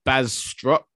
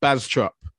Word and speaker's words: Bastrop: And this area, Jax, is called Bastrop. I know I said it incorrectly, but Bastrop, a city in Texas in the US.